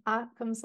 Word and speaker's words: Occam: Occam's